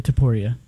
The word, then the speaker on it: Topuria: Ilya Topuria.